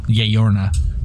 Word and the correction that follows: La Llorona: That's how you pronounce it, right, guys? – No. – La Llorona? – There you go.